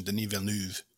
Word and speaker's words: Villeneuve: Yeah, I believe he's Quebecois, so it'd, it'd be more the French ex uh, pronunciation, Denis Villeneuve. Which when I first heard, when I first saw his name, I was like, "Oh, Denis Villeneuve," you know, because I yeah.